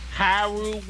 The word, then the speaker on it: Hyrule: Hyrule Warriors.